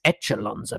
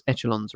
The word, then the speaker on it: echelons: First question, did I pronounce echelons right? – Yeah. Pretty much, yeah. – I would have gone I would have gone the French route, echelon.